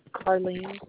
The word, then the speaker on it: Karlene: Carlene Holberg. – Could you spell that first name for me? – K-A-R-L-E-N-E.